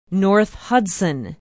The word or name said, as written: north hudson